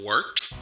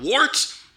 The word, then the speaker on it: Wort: It is The Wort. Not wart, not fort, not short. The Wort.